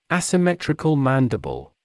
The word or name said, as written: asymmetrical mandible